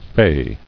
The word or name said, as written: fayed